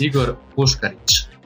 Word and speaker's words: Igor Puškarić: Right? Incorrect. Almost. For the good people out there, why don't you pronounce it so that, you know, we get it right for the rest of the podcast? – Igor Puskaric. It's Slavic.